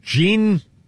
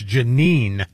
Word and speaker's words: Jeanine: Judge Jeanne Pirro? – Yes, Jeanne Pirro. – I can't ever say her name properly. It's Jeanine. – Yes. – Not Jean.